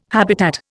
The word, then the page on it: habitat: Indian